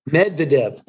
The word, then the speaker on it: Medvedev: Medvedev.